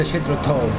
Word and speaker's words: Jethro Tull: se envía Crimson, vía Yes, vía Nelson Emerson Palmer, vía La primera vez que vino Jethro Tull, que en realidad se pronuncia... Me enteré que se pronuncia Jethro Tull.